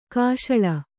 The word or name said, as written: ಕಾಶಳ